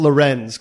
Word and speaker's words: Laurens: I think it technically is pronounced Lorenz, because there's no Lawrence. – C or W.